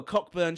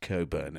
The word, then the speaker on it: Cockburn: He sits down And the guy, and he looks at some sherry and goes, oh, Cockburn sherry. – Yeah. – And the guy next to him goes, actually, sir, it's pronounced Coburn.